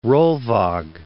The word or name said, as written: Rolvaag